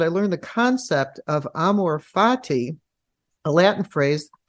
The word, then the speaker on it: amor fati: And I don't know if I'm going to pronounce this correctly, the Latin phrase amor fati. – Perfect.